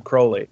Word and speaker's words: Crowley: He wrote a poem called "The Convert," and it has a line in it that goes, "Where are you going so meek and holy? I'm going to the temple to worship Crowley." So he tells us how to pronounce his name.